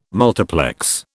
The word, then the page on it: multiplex: British English